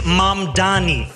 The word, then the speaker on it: Mamdani: The name is Mamdani. M-A-M-D-A-N-I. You should learn how to say it because we gotta get it right.